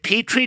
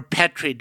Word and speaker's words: Petri: But is it Petri dish or Petri dish? – Oh, Petri all the way. No question. – That is the American pronunciation because I got into it a little with Paige Mackie Murray, who's a brilliant Colorado appellate attorney who said it's Petri, but it turns out that's the British pronunciation.